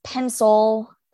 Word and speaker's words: pencil: The word is pencil. Oh, I say, but it's spelled with an I, pencil. I say pencil.